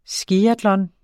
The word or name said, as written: skiatlon